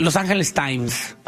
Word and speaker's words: Los Angeles Times: Los Angeles Times.